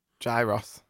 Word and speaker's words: gyros: Is it how you say it? Gyro? – Gyros. – Gyros.